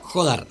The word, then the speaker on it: Jodar: Jodar. – Muy bueno ese. – ¿No? Jodar. Es muy bueno. – Pues acento en la O, ¿no? – Correcto. – Jodar.